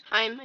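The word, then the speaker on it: Haim: Heim, him. – Heim, I think. – Yeah. – You said that right.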